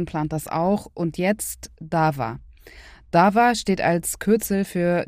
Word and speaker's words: DAVA: Und wenn Sie sich wundern, warum ich das V in DAWA weich ausspreche, Dava ist auch ein türkisches Wort beziehungsweise ein arabisches und hat viele Bedeutungen, zum Beispiel Prozess.